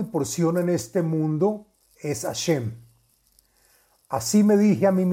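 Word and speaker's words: השם: del santo nombre que se escribe Hei Shin Mem y se pronuncia Hashem.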